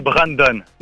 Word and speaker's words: Brandon: C'est Ramdan ton prénom, c'est ça? Ça se prononce comme ça? – Ah, c'est Brandon.